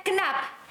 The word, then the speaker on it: Knapp: Knapp, porque es K-N, como cuando pronuncias no, sí, no, Knapp.